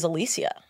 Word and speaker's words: Alicia: She was like, a lot of people think my name's Alicia, but it's Alicia.